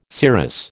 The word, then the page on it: serous: American English